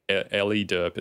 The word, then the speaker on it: Eliderp: Ellie Derp. It's spelled E-L-I-D-E-R-P.